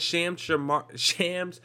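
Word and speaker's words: Shams Charania: And for you guys who don't know, Adrian Wojnarowski and Shams Charmania, that's how you say it,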